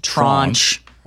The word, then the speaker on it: tranche: By the way, tranche is actually pronounced tranche. – It is a French word that means slice or cut.